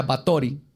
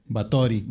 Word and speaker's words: Báthory: Pues, de hecho, investigando este caso, descubrí que se pronuncia Batori. Y así le voy a decir porque está padre. Batori.